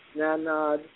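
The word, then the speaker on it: Csanád: So his first name is spelled C-S-A-N-A-D, Zanad, and his last name is spelled Zengedi. S-Z-E-G-E-Z-I.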